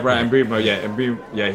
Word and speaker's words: Mbeumo: and Mbeumo, how do you pronounce it? – Yeah, Brian Mbeumo.